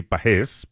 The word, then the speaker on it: Pages: Y si escuchaste a José Mota y escuchas que dice de Pajés, es que así es, así es que realmente se pronuncia Andy Pajés, pero obviamente Andy pues ha dejado que le llamen Pajés porque ya es de costumbre, pero correctamente es como lo está anunciando y pronunciando José Mota, Pajés, que le está yendo muy bien a Andy Pajés en esta temporada.